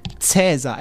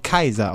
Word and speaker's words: Caesar: Der Kaiser, nicht wahr, der Imperator, der Kaiser, also meine Lateinlehrerin hat übrigens immer gesagt, dass Cäsar eigentlich Kaiser ausgesprochen wird, wegen AE, und wir, das, wir Idioten immer nur Ä sagen für AE, aber eigentlich heißt es Kaiser, macht ja auch etymologisch Sinn.